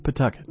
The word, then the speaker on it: Pawtucket: It is spelled Pawtucket, but it is pronounced Pawtucket. The W is silent, maybe even the A. Pawtucket, perfect for freshening up any Nantucket-based dirty limerick.